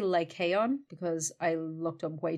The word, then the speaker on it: Lycaon: The one that said, I think this might be modern Greek, but they said Lycaon, so I'm going to go with the modern Greeks.